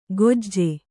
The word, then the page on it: ಖೊಜ್ಜೆ: Kannada